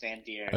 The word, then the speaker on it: Zandier: And then we have Zane Zandier, who I learned this week is pronounced Zandier. – Oh, it's not Zandier. – Yeah, typical UVA French pronunciation.